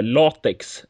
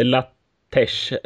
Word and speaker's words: LaTeX: och sen nämnde du ett annat program som jag alltid har kallat för latex, eh, inte latesh eller vad du latesh. Ja, jag, jag uttalade det fel jättelänge också.